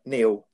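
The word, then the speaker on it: Neal: Well, all they do, they just go into YouTube and they search for me, not me, not M-E, but Neil Zeta.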